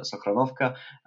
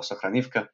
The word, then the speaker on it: Sokhranivka: Just for the reminder of our listeners, Russian gas enters Ukraine via two interconnection points, the larger one at Sudzha and the smaller one at Sokhranovka, or Sokhranivka, to give it its Ukrainian pronunciation.